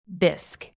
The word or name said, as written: bisque